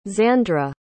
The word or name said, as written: xandra